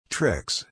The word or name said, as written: tricks